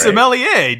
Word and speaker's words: sommelier: It can't be pronounced sommelier, can it? – It's sommelier.